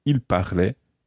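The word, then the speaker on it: parlaient: And in fact, the il plural form is spelt P-A-R-L and the ending is A-I-E-N-T. Il parlait. – Il parlait. – So, again, there's a silent E-N-T at the end of the il form.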